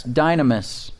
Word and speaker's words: dynamis: D-Y-N, as in Nancy, A-M, as in Mary, I-S, dynamis.